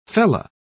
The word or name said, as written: fella